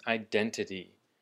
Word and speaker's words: identity: And our last word for today is identity. Identity, which is 身份 or 正身. And the emphasis for the word identity, just like identify, is on the den, on the second syllable.